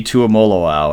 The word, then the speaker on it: Tuimoloau: JT Tuimoloau or something like that. – You nailed it.